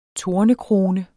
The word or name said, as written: tornekrone